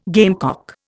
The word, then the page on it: gamecock: Indian